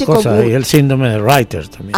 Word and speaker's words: Reiter: Se escribe Reiter, se pronuncia Reiter.